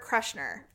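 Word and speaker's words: Kreischer: I drugged Daryl to see Burt Crusher.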